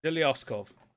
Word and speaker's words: Zhelyazkov: Zhelyazkov.